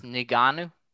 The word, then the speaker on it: Ngannou: And his opponent, how do you say Francis Ngannou.